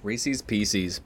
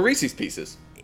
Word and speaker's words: Reese's Pieces: You do peanut M&Ms or Reese's Pieces. – God, Hove Camp. – Yep. – You're pronouncing it how it is spelled, which is, I guess, correct, but it's Reese's Pieces.